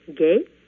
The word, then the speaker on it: Gay: My last name, Gay, is pronounced the same way in English or in French.